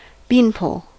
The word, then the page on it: beanpole: American English